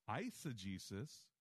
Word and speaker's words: eisegesis: Eisegesis is EISEGESIS. Let me say it again. EISEGESIS means to lead into. Exegesis, lead out of. Eisegesis, lead into.